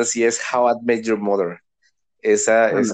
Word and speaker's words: How I Met Your Mother: How Do You Say the Mother? – No. – ¿Cómo conocí a su madre? – Ah, how I met your mother.